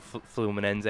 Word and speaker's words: Fluminense: You know, I think I looked yesterday, 22, played, I think, over 140 games in the Brazilian, well, for Fluminense.